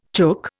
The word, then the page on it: chook: Indian